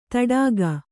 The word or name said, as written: ಥಟ್ಟುಗಿ